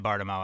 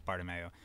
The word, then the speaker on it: Bartomeu: Is it Bartomeu? – Yeah, I think it is. – And I can't even say it right. It is Bartomeu. – A lot of people say Bartomeu. I don't think that's like crazy wrong, but I think it's Bartomeu.